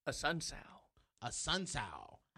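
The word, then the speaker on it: Assunção: A Sun Sal.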